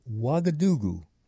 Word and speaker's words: Ouagadougou: And the pronunciation I was familiar with, it was called Ouagadougou.